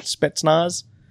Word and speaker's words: Spetsnaz: Did I say that right? Spetsnaz? – Yeah, you got it right.